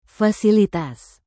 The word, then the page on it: fasilitas: indonesian